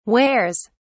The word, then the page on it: wears: British English